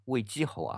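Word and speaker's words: 魏其侯: 一个呢，就是魏姬侯窦婴啊，魏姬侯啊，写出来是其他的其，但是在这里边要读成姬，魏姬侯窦婴，他是汉武帝的奶奶窦太后的侄子，也就是汉武帝的表舅舅。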